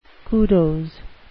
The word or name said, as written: kudos